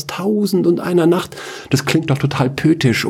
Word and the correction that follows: poetisch: Pötisch. Ja, pötisch. Du meinst poetisch. Ah, ach so, äh, äh, so spricht man das aus.